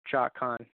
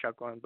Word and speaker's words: Chacon: Um, if you still have trouble, everybody, just say Cha Khan. Cha Khan. I get a lot of people that you know that calls me like that when people call me by phone or something, and then I'll be like, "Yeah, it's pronounced Chakwan, but it's okay."